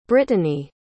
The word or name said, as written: brittanie